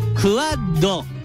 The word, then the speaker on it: QUAD: QUAD と書いてクワッド。